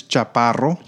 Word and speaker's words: Chaparro: In Spanish, it's Chaparro.